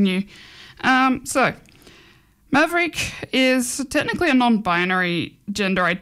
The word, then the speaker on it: Mavreiqe: So now we're looking at our LGBT of the week, which is Maverick. So it's spelt M-A-V-R-E-I-Q-E. So that's why it's a bit funny pronouncing.